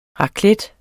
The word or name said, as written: raclette